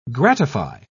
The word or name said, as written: gratify